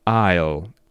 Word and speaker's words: aisle: But the thing is, the word is pronounced aisle, and it's written as A-I-S-L-E. So remember, the two words, island and aisle, are pronounced without S. You can see the S written, but it's not pronounced.